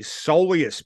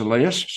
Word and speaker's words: soleus: But Jacob Hopper, he has suffered a strain to the soleus portion of his calf, which is in the lower portion of the leg, I'm told by the AFL calf doctor. Can't remember his name. – It's actually pronounced soleus.